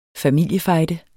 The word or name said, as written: familiefejde